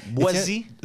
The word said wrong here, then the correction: Boétie: B-O-E-T-I-E. Boétie. De la Boétie. – Boétie, OK. – Boétie, OK. Bon, mais c'est pas des S, là, mais c'est un T. Ça se prononce Boétie.